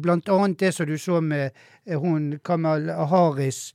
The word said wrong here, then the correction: Kamala Harris: hun kan jo snøre når jeg ikke uttaler navn, nei. Kamala Harris.